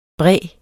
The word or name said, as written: bræ